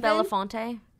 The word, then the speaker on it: Belafonte: Mel B had filed for divorce and received a restraining order from her allegedly abusive husband, Stephen Belafonte, last time. That's how you say his name, right? – Yes. Stephen Belafonte.